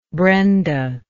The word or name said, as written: brenda